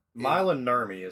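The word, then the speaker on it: Maila Nurmi: Mila Nurmi is how you pronounce that, by the way. – Is it Mila Nurmi? – Yeah, it was going to drive me nuts if you kept saying it that way.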